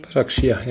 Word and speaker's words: Başakşehir: Başakşehir? – Başakşehir, ja, ja.